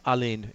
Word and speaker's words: Alun: It's pronounced Alin.